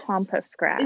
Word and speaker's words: pampas grass: Like, if I got married this year or next year, like, there would be so much pampas grass. Am I even saying that right? – Yeah, you are. I think pampas grass.